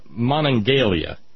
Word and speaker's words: Monongalia: And he is theoretically from Monongalia County. No, I did not just mispronounce Monongahela. That's the name of the river.